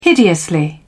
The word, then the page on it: hideously: British English